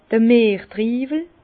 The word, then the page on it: d'r Mertriiwel: Bas-Rhin; Herrlisheim